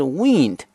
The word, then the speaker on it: wind: 弯曲这个词，大家如果单看这个词的话呢，往往会觉得跟风有关系，因为这个词的前几个字母跟这个风是一模一样的，W-I-N-D，但是这个词跟风没有关系。另外它的发音跟风也是完全不一样的。我们知道风的发音呢是 wind，但是呢，如果指一个东西是弯曲的话呢，它的发音变成了 wind。